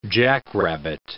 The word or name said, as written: jackrabbit